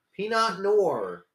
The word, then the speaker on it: Pinot Noir: I don't even know if you're supposed to pronounce the R at the end or like Pinot Noir. – Pinot Noir.